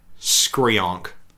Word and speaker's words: Skreeeonk: Yeah because it's sort of described as disyllabic, which like as opposed to monosyllabic. There's definitely two syllables, and the way in like Godzilla comics, it's the onomatic payer is S-K-R-E-E-E-O-N-K, Skreonk.